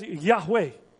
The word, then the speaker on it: Yahweh: And we pronounce YHWH as Yahweh.